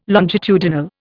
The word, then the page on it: longitudinal: Indian